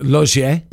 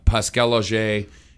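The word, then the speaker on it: Laugier: I think the last name is pronounced Lagier. – It depends. Logier. – Yeah, Pascal Logier.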